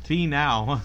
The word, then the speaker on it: Finau: Finau. – Yeah, Finau. That's Finau. Right. – That's it. Is Finau.